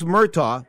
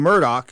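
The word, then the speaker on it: Murdaugh: He's got a guy by the name of Alex Murtaugh. They call him Murdoch. That's how he wants to pronounce his name.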